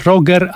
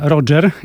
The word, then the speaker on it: Roger: Właśnie Roger, a nie Roger, jak się okazało, bo to tak powinno się wymawiać imię lidera tego Big Bandu.